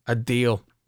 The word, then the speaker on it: Adeel: A deal. I got a deal A-D-E-E-L, for you. – A-D-E-E-L, Alam.